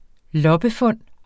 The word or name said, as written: loppefund